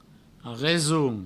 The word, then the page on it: reson: resoun; Provençal